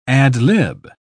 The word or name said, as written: ad-lib